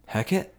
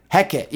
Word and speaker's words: Hecate: Hecate? – Hecate, yeah.